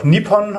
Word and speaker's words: Nippon: Nihon ist ja Japan. Manchmal auch Nippon ausgesprochen.